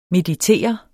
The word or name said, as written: meditere